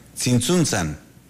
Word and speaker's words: Tzintzuntzan: Dice que esta población de Michoacán se pronuncia Tzintzuntzan.